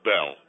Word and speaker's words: Bell: I'm Ted Bell, okay? Ted Bell. T-E-D-B-E-L-L.